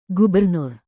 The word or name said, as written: gubernur